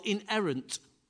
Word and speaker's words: inerrant: It's called inerrant. Inerrant. It's inerrant. The word's not only, it's spelt. Oh, where's it gone now? I've lost it on me. Oh, I-N-E-R-R-A-N-T. Inerrant, which means its history, its stories, events, its teachings all match up.